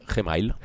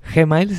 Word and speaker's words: Gmail: Pues tengo el correo, que es el que atiendo personalmente, que es elgordogt@gmail, o como le dicen acá la pronunciación. – Gmail. – Gmail, sí, sí.